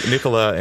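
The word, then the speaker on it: Nicolas: I found out after all these years that you don't actually pronounce the S in his first name. Nicola,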